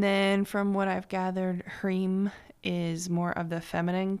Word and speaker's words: Hrim: So today's mantra is Om Harim, which is, and Harim, you probably know Om is spelled O-M, and Harim is spelled H-R-I-M, pronounced Harim.